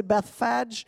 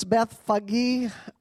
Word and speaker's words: Bethphage: Now when they came near to Jerusalem, to Bethphage, I looked that up because I wanted to say Bethphage, but it's Bethphage.